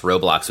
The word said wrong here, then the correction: Roblox: with a French accent or something. That was interesting. But anyway, I'm not so hip as to be on Roblox, but at least I know it's pronounced Roblox.